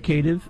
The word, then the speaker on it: canid: It's in the canid.